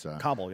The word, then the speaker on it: Kabul: We had the contingency plans in place for any eventuality, including a quick fall of Kabul. I don't even know if I'm saying that right, by the way. But Kabul, yeah.